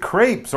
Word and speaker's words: crêpe: So, are you familiar with crepes or crepe to give them the proper French Ooh. pronunciation? Because they are French.